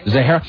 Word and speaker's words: Zahara: How do you pronounce that? Zahara? – Zahara.